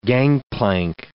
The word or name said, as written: gangplank